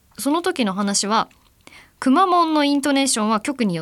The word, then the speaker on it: くまモン: ちょっと前の話になりますが、シャープ 75 でくまモンの発音について今迷っちゃったな、福岡のテレビ局 TNC のアナウンサーは、みなくまモンというイントネーションだったという話をされていましたね。こう平板という、アクセントですね。